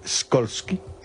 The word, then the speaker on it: Skolsky: ¿Cómo se pronunciaría en polaco Skolsky? – Skolsky. Como tú lo has dicho.